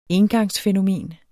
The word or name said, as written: engangsfænomen